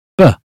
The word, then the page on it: book: British English